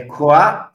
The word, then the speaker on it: Croix: Na Coafra. Como é que fala? Coafri? Peraí, deixa eu ver. É, é, é croix, croix, né? Cruz, free. Croix. croix.